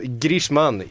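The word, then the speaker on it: Griezmann: Eh, Antoine Liesman, Leisman, Eh, kanske skulle uttala som, som Grishman, Grishman ska man tydligen säga.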